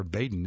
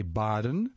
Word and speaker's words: Badin: Baden is pronounced over. The German in me wants to say Baden, but I checked the internet, the source of all things, and it says it's pronounced Baden, B-A-D-I-N.